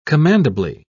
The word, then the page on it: commendably: British English